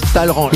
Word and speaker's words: Talleyrand: Ça s'écrit Talleyrand, mais ça se prononce Talleyrand.